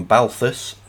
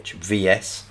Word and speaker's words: Balthvs: And it's from Balthus, that's spelt with a V, so it's Balthvs.